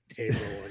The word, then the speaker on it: Haverhill: I think they look at it as the word is have, right? But I guess. Gotcha. – Yeah, I explained very clear. I'm like, no, we say Haverhill.